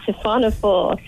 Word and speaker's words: siphonophore: It's called the siphonophore. This is stepping away from the Keeling Curve right now, but this is so fascinating. Is that the right pronunciation? And what is that? – Yes, the siphonophore.